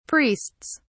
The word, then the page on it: priests: British English